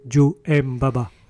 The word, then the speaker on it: 9m88: Elle s'appelle 9M88, mais il faut prononcer Joe M. Baba.